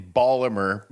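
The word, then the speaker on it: Baltimore: He was born in Ballamer. – B-town. – That's how you say it.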